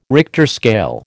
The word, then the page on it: Richter scale: American English